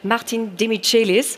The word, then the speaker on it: Martin Demichelis: Kümmern uns aber erstmal um beispielsweise Martin Demichelis. Achtung, ja, so wird er offensichtlich richtig ausgesprochen, hat er jetzt auch nochmal erklärt, auch wenn das jahrelang anders fabriziert wurde.